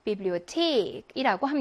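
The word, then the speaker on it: Bibliothek: 태하는 독일어에서 무슨 발음이죠? 트 발음을 내는 경우예요. 그래서 bibliothek이라고 합니다. 이 bibliothek이라고 하는 단어는 강세도 주의하셔야 돼요. 강세 여기 있거든요.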